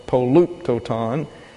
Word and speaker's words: polyptoton: P-O-L-Y-P-T-O-T-O-N. And it comes from the Greek word paluptoton. P-O-L-Y-P-T-O-T-O-N. U-P-T-O-T-O-N, polluptoton, accents on that second syllable there.